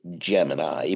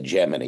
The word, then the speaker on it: Gemini: Gemini, but they weren't really familiar with the pronunciation.